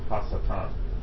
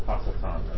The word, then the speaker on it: Hasatan: That would be pronounced in Hebrew, Hasatan. Hasatan.